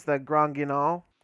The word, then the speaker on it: Le Grand Guignol: I believe it is Le Grand Guignol. – If you want to go for the, like, American pronunciation, it's the Grand Guignol.